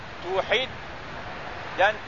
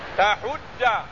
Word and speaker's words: tuhidda: Bacanya macam mana? Kata Syekh, bidhammita tu. Wa kasril ha. Tuhid. Dan tashdid dalnya tuhidda dan boleh baca tahudda itu makna wa yajuzu fathit ta'i wa dhammil ha artinya tuhidda boleh tuhadda pun boleh ruba'iyun ruba'iyun artinya daripada fi'il rubai.